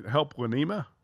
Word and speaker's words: helplinema.org: Helplinema? – Helplinema. I did. – Say like Helpline Ma or something.